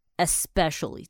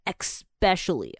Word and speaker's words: especially: 好，再来看到一个常念错的 especially，这是正常的念法，很多人会念成 especially 啊。 especially 这个字是特别的意思，但不知道为什么很多人对 x 这个字很执着，都会想要加一个 x。